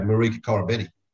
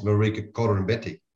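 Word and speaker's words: Marika Koroibete: Like 3 years ago, Marika Corabetti, that's how you pronounce his name. And then 18 months ago, he sends us a video to Sean Maloney and the crew and says, it's Marika Corabetti.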